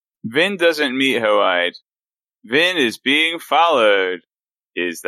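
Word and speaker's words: Hoid: Hoide, the Ska informant. – I just wasn't expecting you to pronounce his name like that. – Well, he's from Elantris originally, so that's how his name is said.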